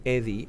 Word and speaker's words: EDYE: We call it EDY. EDY is spelled E-D-Y-E.